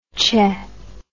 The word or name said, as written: che